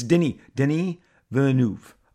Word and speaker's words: Denis Villeneuve: But no, it's Denis Villeneuve.